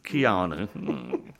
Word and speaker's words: Keanu: I remember when he first came out, hey, how did you say this guy's name? The canoe? Keanu? – I think that was, yeah, Keanu, Kanunu, Kanuni, yeah. Kanui, yeah. How do you say his name? – Keanu. Thank you.